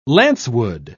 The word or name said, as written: lancewood